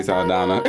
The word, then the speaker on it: Saldana: Oh, Zoe Sonata? – No. – Soldata? – No, that's not butcher her name. – Zoe Saldana.